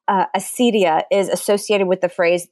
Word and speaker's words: acedia: So maybe you can tell us why that is as part of your explanation of sort of like, what is acedia? – Well, probably just people don't want to say akedia, which is the Greek, probably something like the Greek pronunciation.